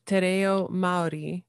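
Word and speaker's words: Te Reo Māori: So I picked up, you're saying Te Reo Maori. Am I pronouncing that kind of right? A little bit? – Yes, that was very good.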